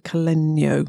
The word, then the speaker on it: Caleño: which is just as well because we've got Ellie Webb of Caleño. I pronounced it right, Ellie.